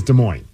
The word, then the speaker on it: Des Moines: I feel like I've been told many times that we say it Des Moines with the S, but then I've also heard people say, no, the S is silent, so I don't know.